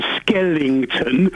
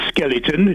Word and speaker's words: skeleton: First of all, when you were reporting on Sochi, you were going on about Skellington. – Yeah, the Skellington. – No ING is skeleton.